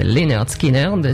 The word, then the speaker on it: Lynyrd Skynyrd: C'est Lynyrd Skynyrd qui sort son premier album en 73, pronounced Lynyrd Skynyrd, si avec ça on ne sait pas prononcer le nom du groupe.